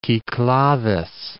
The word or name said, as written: Kikladhes